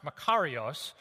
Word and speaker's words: makarios: It's a word it's pronounced makarios, it's a word that means divine joy and supreme and utter and perfect happiness and satisfaction.